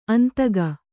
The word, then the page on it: ಅಂದಿಕೊ: Kannada